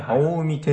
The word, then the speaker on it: 青海: ああ、梅ね。じゃないですよね。青い海と書いて、はい青海展示塔ということですね。